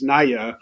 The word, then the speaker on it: Naja: which belong to the genus Naya, uh, but it's spelled Naja and AJA if anyone wants to look it up.